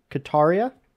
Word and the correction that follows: Katana: Katana. Sorry, Katana. There was something on my screen.